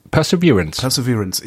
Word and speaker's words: Perseverance: Perseverance, also spricht man den aus? Okay. – Perseverance.